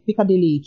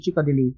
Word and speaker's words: Chikodili: I mean, I give people the mnemonic device of Chikodili, Chikodili, you know, so that tends to work.